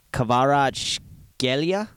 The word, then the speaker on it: Kvaratskhelia: The last name is pronounced Kvaratskhelia. Kvaratskhelia.